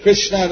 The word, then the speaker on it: Krishna: Ili they put a T in it, not T. Krishna doesn't have a T, but they put Krishna.